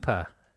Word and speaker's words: Cupar: C-U-P-A-R. How would you pronounce that? Where's that? – Cooper. Cooper? – Cooper.